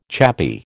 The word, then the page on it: chappy: American English